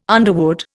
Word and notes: underwood: Indian

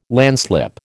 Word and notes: landslip: American English

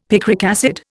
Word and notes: picric acid: Indian

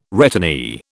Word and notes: returnee: British English

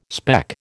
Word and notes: speck: American English

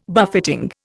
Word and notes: buffeting: Indian

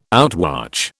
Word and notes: outwatch: British English

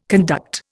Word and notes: conduct: Indian

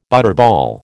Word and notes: butterball: American English

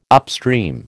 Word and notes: upstream: American English

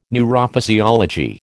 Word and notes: neurophysiology: American English

